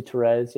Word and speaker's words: Therese: St. Therese, how do you pronounce it? – I think. Therese. I've heard mostly Therese.